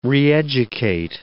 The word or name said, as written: reeducate